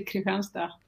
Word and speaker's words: Kristianstad: And now you are at Kristianstad, is that right? Kristianstad. Yeah, and you've been there for almost, yeah. – If you pronounce it in Swedish, you would say Kristianstad. – Kristianstad. That sounds almost Danish.